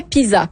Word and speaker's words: PISA: Je rappelle que le PISA, en fait, ouais, PISA plutôt, hein, parce que c'est un S entre deux voyelles, ça se prononce comme un Z.